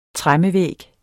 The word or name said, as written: tremmevæg